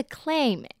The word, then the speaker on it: claim: 那中间 ai 跟 a 的发音不太一样，我在这边强调一下，宣称是 claim ai 发。 A 的音，那蛤蜊 clam A 呢自发 A 的音 A 跟 A claim clam，这样有没有搞懂？